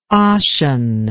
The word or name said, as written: ossian